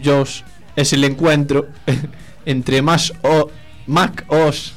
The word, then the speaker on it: Mac OS: Como decíamos anteriormente, Apple ha presentado oficialmente la próxima versión del Mac OS X. Se dice así, nunca lo supe. – Sí, es Mac OS.